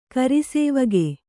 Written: ಕರಿಸೇವಗೆ

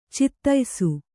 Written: ಚಿತಾಯಿಸು

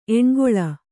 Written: ಎಣ್ಗೊಳ

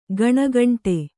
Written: ಗಣಗಂಟೆ

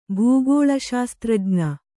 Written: ಭೂಗೋಳಶಾಸ್ತ್ರಜ್ಞ

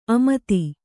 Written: ಆಮೋದ

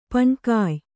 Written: ಪಣ್ಕಾಯ್

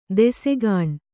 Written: ದೆಸೆಗಾಣ್

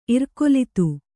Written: ಇರ್ಕೊಲಿತು

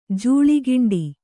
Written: ಜೂಳಿಗಿಂಡಿ

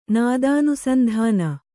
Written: ನಾದಾನುಸಂಧಾನ

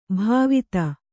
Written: ಭಾವಿತ

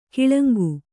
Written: ಕಳಂಕ

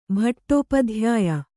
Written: ಭಟ್ಟೋಪಧ್ಯಾಯ

